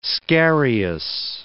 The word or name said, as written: scarious